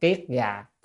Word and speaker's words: kiết già: À, kiết già cái đầu nó có người đọc là kết, nhưng mà đọc kiết già phu tọa tức ngồi kiết già 6 năm.